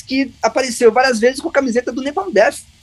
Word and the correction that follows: Napalm Death: Agora eu falei errado, é Napalm Death.